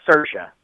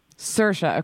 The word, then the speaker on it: Saoirse: Saoirse. – Saoirse, okay.